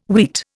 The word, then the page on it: wheat: Indian